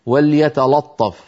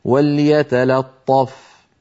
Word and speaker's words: وليتلطف: وليتلطف يقول أحدهم وليتلطف، فهذا لا يصح، بل عليه أن يقول وليتلا. وليتلا بعد أن ينطق اللام مرققة ينتقل إلى الطاء المفخمة فيقول وليتلطف.